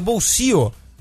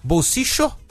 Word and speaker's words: bolsillo: O caçapo em espanhol, que se pronuncia bolsio. Que tem as três pronúncias do duplo L lá na Argentina, isso. – Né? Bolsicho, como se fosse um CH, né?